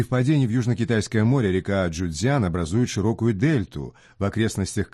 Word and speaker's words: Чжуцзян: Чжуцзян, река Чжуцзян. Первый иероглиф «чу» произносится в первом тоне.